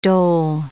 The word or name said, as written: dhole